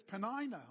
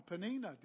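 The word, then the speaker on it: Peninnah: But it's worse because Penina or Penina, depending how you pronounce it in the Hebrew, both ways are entirely possible.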